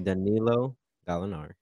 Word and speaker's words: Danilo Gallinari: Danilo Gallinari.